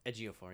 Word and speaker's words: Ejiofor: Is that how you say his name? Ejiofor? – Ejiofor, yeah.